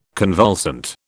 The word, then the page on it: convulsant: British English